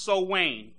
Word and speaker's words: Samhain: The second one is spelled Samhain, S-A-M-H-A-I-N, but it's pronounced Sowain.